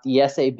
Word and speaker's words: IESE: As you know, in Spanish, it's pronounced IESE Business School.